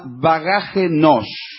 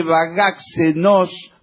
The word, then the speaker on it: Bagaxe: Entonces en lugar de bagaxe nos, como dijeron varias veces, se pronuncia bagaje nos.